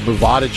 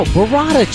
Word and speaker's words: burrata: You never heard of Bovada cheese? Is it Bovada? – No, burrata cheese. – It's Bovada. – No, it's burrata cheese. – Well, you don't have to roll your R's like a smart ass. – Yeah, I am going to. – What is it, burrata? – Burrata cheese, yeah.